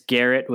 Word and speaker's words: Garrett: It's Garrett with one R and one T.